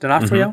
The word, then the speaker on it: D'Onofrio: Is that how we say that right? D'Onofrio? Mm-hmm.